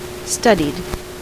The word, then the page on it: studied: American English; /ˈstʌd.id/